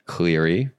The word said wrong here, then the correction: Clary: It's Clary.